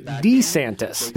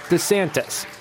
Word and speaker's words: DeSantis: She's credited with carefully managing his public image, even changing how he pronounces his name. He used to say DeSantis. – This is Governor Ron DeSantis. – But she persuaded him to soften the first syllable to DeSantis.